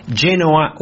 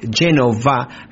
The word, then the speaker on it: Genova: And they are, I think originally from the north part of Italy, Genoa or Genova, how it's said in Italy, and it's where my mother's side of the family is from.